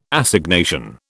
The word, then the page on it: Assignation: British English